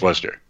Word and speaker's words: Worcester: Most people would say Worcester. – Right. You know? Yeah. Exactly. – But it's Worcester, and we pronounce it more like the English do because they're all English names.